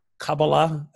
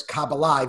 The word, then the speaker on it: Kabbalah: If there's anything, two things I learned is that I've been pronouncing Kabbalah. I've been saying Kabbalah and Shekinah. Kabbalah, I've been mispronouncing those words for years, apparently. – It's just like an anglicized versus Hebrew pronunciation,